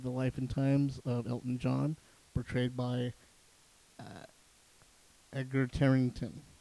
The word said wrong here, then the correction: Taron Egerton: Edgar? Did I say it right? Edgar? Egon, Mac Daddy. Anyway, the guy from Kingsman. Yes. I'm going to say it wrong until I say it wrong. Taron. That's it. Taron Egerton.